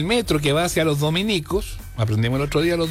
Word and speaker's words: Dominicos: Si se alude a los religiosos de la Orden de Santo Domingo, se escribe Dominico, sin tilde en ninguna I. Por lo tanto, es la estación Los Dominicos, la que queda en Las Condes.